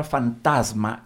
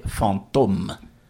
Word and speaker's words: fantôme: Es muy interesante que la palabra fantasma en francés se dice fantôme.